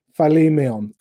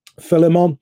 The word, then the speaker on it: Philemon: The name Philemon, is the way you would pronounce it. I prefer just to call it Philemon, and I'm sure he won't mind me mispronouncing his name, but it's easier to say.